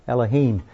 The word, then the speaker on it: Elohim: Elohim.